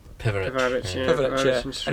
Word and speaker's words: Pivarić: Pivarić.